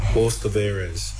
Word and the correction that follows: hors d'oeuvres: He's trying to get to hors d'oeuvres there. – Oh, it was hors d'oeuvres.